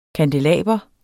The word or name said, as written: kandelaber